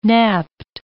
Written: napped

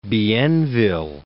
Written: Bienville

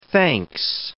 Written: thanks

